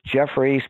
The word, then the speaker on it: Geoffrey: It's Jeffrey, spelled with a G.